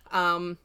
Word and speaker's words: Pusheen: it literally is, I looked it up how to pronounce it. It's literally pronounced the same way. – Pusheen. Pusheen. – Yeah, so it's said the same in Irish as it is in English. It's just spelled differently.